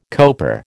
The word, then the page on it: coper: American English